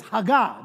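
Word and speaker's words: hagah: And then you have this other word, I know how to pronounce this, haggah. There you go, come on.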